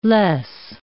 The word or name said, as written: loess